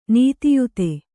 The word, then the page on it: ನೀತಿಯುತ: nītiyuta